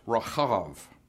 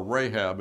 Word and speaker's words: Rahab: and other times spelled C-H-A-N-U-K-K-A-H. Rahab the harlot of Jericho fame is spelled with the hard H. And in Hebrew, her name is pronounced Rahav, or Rahab in English.